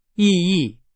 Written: 意义

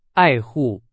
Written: 爱护

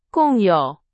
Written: 共有

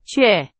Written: 却

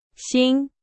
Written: 型

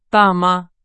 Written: 大妈

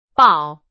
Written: 宝

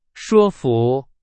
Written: 说服

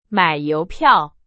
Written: 邮票

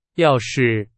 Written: 要是

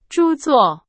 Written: 著作

